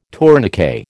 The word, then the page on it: tourniquet: American English